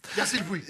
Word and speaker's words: Puig: Besides, Yasiopoulos. – the way you pronounce that, it sounded like you were saying something very different than a human's name. Yasi Puig.